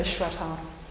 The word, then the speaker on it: Shraddha: So, a little bit about Shraddha, which is spelt sometimes Sraddha, and sometimes it's got an H in there as well. And you generally say Shraddha.